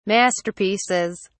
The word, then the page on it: masterpieces: British English